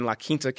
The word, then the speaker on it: La Quinta: Zach Blair and Daniel Summerhays teeing off at the Career Builder Challenge at PGA West in La Quinta, California. – Nice pronunciation of La Quinta. – Well, that's a little bit easy for me, because when I played here, we stayed at the La Quinta.